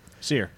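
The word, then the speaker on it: Cere: So his relationship with, I always say it wrong, Seer? – Seer. – All right. I thought, like, you see her steak.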